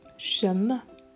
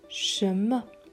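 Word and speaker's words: shemme (什么): Also du, ni, heißen, jiao, die vierte Betonung, jiao, was, shemme, shemme zweite Betonung und hier M, E. das ist der sogenannte Fünftton oder der Neutralton.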